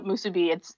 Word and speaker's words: musubi: If you look up musubi, it's spelled exactly how it sounds. Musubi.